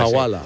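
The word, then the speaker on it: Hawala: Y el Wajala, creo que se dice así. – Sí, la Jawala. Jawala. Sí.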